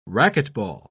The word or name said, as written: racquetball